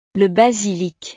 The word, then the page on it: le basilic: frances